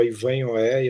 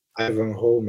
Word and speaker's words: Ivanhoe: Eu estava lendo Walter Scott, eu estava lendo aquele livro que quando eu era criança eu chamava Ivan Oé, e eu ainda continuo falando Ivan é, mas obviamente não pronuncio Ivan Oé. Ivanhoe, imagina. Ivanhoe, é.